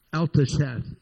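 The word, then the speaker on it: Al-taschith: Alta Sheath is the right way to say it.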